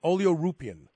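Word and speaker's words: oleuropein: More and more research is being done on things like oleoupin. Am I pronouncing that right? – Yes.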